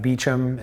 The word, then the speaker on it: Beauchamp: It's Adam Beauchamp. It's spelled B-E-A-U-C-H-A-M-P, and I'm the chief production officer.